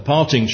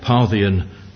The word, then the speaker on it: Parthian: And of course it's from that that we get the phrase, the Parthian shot, although very often we mispronounce it and we call it the parting shot. But actually, it is the Parthian shot.